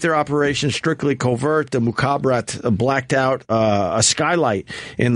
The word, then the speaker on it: Mukhabarat: M-U-K, Mukhabarat. H-A-B-A-R-A-T. – Mukhabarat.